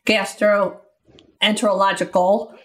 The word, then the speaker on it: gastroenterological: I should be able to pronounce gastroenterological. I left off the last syllable.